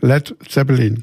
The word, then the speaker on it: Led Zeppelin: Eins in dieser Richtung haben wir noch, und zwar Led Zeppelin. Ich hoffe, es hat richtig gesagt. Led Zeppelin, genau, ja.